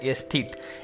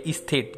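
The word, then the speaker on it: aesthete: एस्थिट एस्थिट spelling है ए E S T H E T E S T H E T एस्थिट या स्थित जो भी आप बोलें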